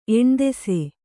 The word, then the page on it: ಎಣ್ದೆಸೆ: Kannada